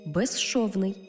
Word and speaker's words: безшовний: У словах безшовний і безшумний, свистячий з плюс шиплячий ши всередині слова разом вимовляємо як жи – ши.